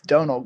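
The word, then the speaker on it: Domhnall: They said, you're saying it wrong. It's Donal Gleason.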